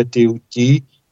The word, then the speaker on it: Thoth: através de um grande ser chamado Thoth. Em egípcio se pronuncia Teuti, egípcio antigo, o deus Thoth, que é conhecido como Hermes Trismegisto, o Hermes três vezes grande.